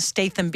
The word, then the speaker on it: Statham: Nej, nej, så kigger hun på mig, så siger hun, it's Jason Statham, because it's with a T and an H, which goes th.